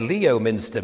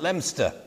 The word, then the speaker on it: Leominster: It's spelt Leominster. And when I got there, I said Leominster because that's what it said on my map. And people laughed and I was embarrassed because I didn't realise that everybody pronounced it Leominster.